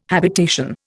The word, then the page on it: habitation: Indian